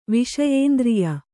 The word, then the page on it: ವಿಷಯೇಂದ್ರಿಯ: kannada